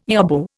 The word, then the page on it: yobbo: Indian